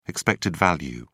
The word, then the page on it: expected value: British English